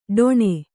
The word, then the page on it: ಢೊಣೆ: Kannada